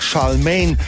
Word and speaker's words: Charlemagne: Eine Britin eben mit französischem Namen. Charlemagne, Charlemagne. Sprich es einfach Charlemagne, hat mir nun jemand geraten, den ich heute eigens kontaktierte, weil er einige Jahre in Manchester verbracht hat.